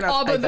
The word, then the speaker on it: ABBA: It's like Abba the Father. Yeah, it's Abba.